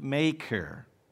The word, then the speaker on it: Machir: And Ziba said to the king, "Behold, he is in the house of Machir," that's how that's pronounced, "the son of Ammiel in Lodabar." Then King David sent and brought him from the house of Machir, the son of Ammiel, from Lodabar.